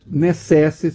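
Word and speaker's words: necesse: necessi se escreve com C, mas se lê K, muito provavelmente.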